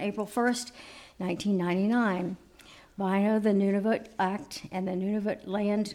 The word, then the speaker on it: Nunavut: Nunavut. Did I say that right? Nunavut? Close enough. Nunavut. Sorry, I missed the N.